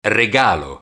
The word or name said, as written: regalo